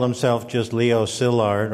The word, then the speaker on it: Szilard: In Hungarian, S is SH, as in Budapest. SZ is S, as in Franz Liszt. And I is E. Leo is pronounced Leo, and Szilard, Szilard.